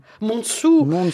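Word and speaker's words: Monsù: Un mot, des Mensou. Comment prononce-t-on Monsou.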